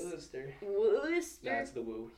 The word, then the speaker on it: Worcester: Okay, because Izzy is from, I'm gonna butcher it, Worcester? It's Worcester! – Yeah. – Yeah, I always want to say Worcester! – Because that's how it looks.